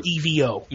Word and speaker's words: Evo: Okay, this past weekend was, is it pronounced Evo or EVO? – Evo.